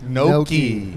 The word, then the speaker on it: gnocchi: Gnocchi. – It is a food. – It's actually spelled with a G.